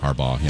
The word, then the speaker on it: Harbaugh: Harbaugh. Is that how you say it? My fault. – Harbaugh, yeah, 2004.